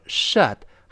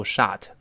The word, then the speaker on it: shut: 那这里读成 shut 还是倒 v 字的这个呃呃，我们跟老师大声读一下，shut，shut。哎，注意不要读 shut，那这老外就听不懂了啊。